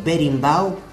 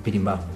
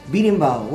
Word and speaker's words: berimbau: ¿Berimbau? – No, Berimbau. – ¿Cómo? – Me escucho con mucho. – Berimbau. – ¿Cómo se dice? – Berimbau.